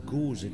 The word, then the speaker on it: Gose: Det, du drejer, det er gose, det skal udtales på tysk.